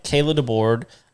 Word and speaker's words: Kayla DeBoard: Well, we have on the show tonight, Kayla DeBoard.